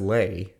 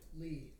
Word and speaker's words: Leigh: How to pronounce L-E-I-G-H. – Lee.